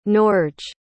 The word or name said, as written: Norge